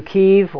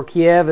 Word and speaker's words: Kyiv: We first went, of course, to the Ukraine, to Kyiv, or Kiev as we pronounce it, and we were only there for about 4 days.